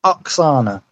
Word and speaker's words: Oksana: But we're getting images of Cesaro on SmackDown with Oksana, I believe is how it's pronounced. – Oksana, okay.